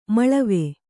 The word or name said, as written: ಮೇಳಾವ